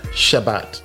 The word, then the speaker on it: Shabbat: So all of this is actually something outside of the Bible, where we actually find evidence of the Shabbat, not Sabbath. It's Shabbat, and that's something that I actually teach quite passionately. – Shabbat. – That we have to call it what it was originally called. Shabbat.